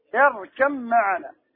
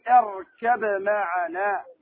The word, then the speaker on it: اركب معنا: اركم معنا، قول نوح عليه الصلاة والسلام لابنه اركم معنا، ما تقول اركب معنا، ما تنطق الباء. هنا الباء أدغمت في الميم، مفهوم؟